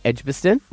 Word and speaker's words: Edgbaston: Edgbaston? – Yep, there you go.